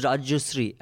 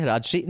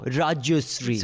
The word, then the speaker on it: Rajyasree: And okay, so you can say my name is Rajyusri, and he'll say Rajshri. No, Rajyusri.